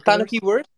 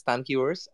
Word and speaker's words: Tanukiverse: How do you pronounce it? Is it Tanukiverse? Yeah, that's Tanukiverse. So speaking right from Tokyo, and so glad to be speaking here with all of you.